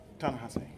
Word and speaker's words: Ta-Nehisi: How do you pronounce Coates' first name we were just talking about? – It's Ta-Nehisi. – Ta-Nehisi.